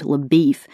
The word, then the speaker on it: LaBeouf: So is a vain, good-looking Texas Ranger named LaBeef, who has already tracked Chaney over several states. LaBeef, whose name is spelled LaBeouf,